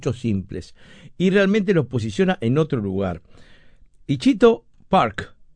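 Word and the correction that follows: Itchycoo Park: Ichiku Park, perdón que lo dije mal,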